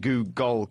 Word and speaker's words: googol: So they called it a Googol, and you could hear him emphasizing Googol because it's spelt G-O-O-G-O-L.